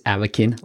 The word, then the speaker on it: Avakin: I actually mispronounce it in the episode, but it's Avakin Life.